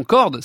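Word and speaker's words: Cord: Parlez-nous de votre ami mathématicien Mathieu Corps. – Oui, alors Mathieu Corde, qui qu'on prononce son nom Corde, C-O-R-D, c'est un exemple, voilà, je prenais un exemple dans ces accidents de collision.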